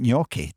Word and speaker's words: gnocchi: Graham Near Howard, who's having Italian sausage and Borlotti bean gnocchi tonight.